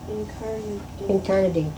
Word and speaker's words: incarnadined: O living creature, gracious and so kind, coming through this black air to visit us who in death the globe incarnadined. – Mm-hmm. Incarnadined, I suppose, to rhyme with kind.